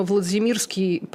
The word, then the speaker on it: Владимирский: Видимо, Владимирский – правильное ударение, потому что польская фамилия.